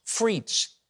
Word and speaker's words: frites: It's pronounced frites. It's a perfume that smells like French fries. Okay. You see, the word frites is French for French fries.